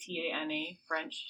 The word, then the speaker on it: Tana French: I saw that, is it Tanya French? How do you say her name? It's like Tana French.